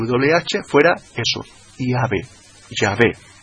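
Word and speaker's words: iabe (Ιαβε): escribe iabe, iota, alfa, beta, epsilon, iabe, como la mejor pronunciación fonética del tetragramatón, mucho antes que los rabinos que hemos dicho antes, los rabinos masoretas, metieran las vocales.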